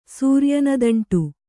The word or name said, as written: ಸೂರ್ಯನದಂಟು